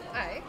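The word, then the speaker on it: Ai (愛): Ai.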